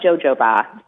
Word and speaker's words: jojoba: It's spelled jojoba. So you may have seen it and not, but you pronounce it jojoba.